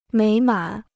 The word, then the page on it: 美满: měi mǎn